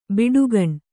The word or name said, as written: ಬುಡಿಗಾನೆ